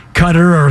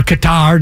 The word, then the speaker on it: Qatar: and Qatar or Qatar, depending on how you want to pronounce it, they're going to get crushed by everyone, but it leads itself to a good gambling opportunity.